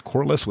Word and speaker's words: Corless: I am at Kirk Corliss, which is spelled K-I-R-K, last name spelled C-O-R-L-E-S-S.